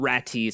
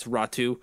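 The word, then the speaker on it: Räty: It is pronounced Ratu-ish differently. It's not E. It's not Ratty. It's Ratu.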